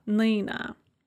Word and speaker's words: Нина: With мила, Лена, and Нина, we have an O or an A that is after the stress, and so they're all pronounced kind of an E sound. Мила, Лена, Нина.